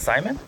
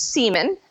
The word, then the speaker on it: Simon: Are they saying his name wrong as Simon? – So in Norwegian, it's Simon, but I think in English, it's Simon, which is appropriate either way.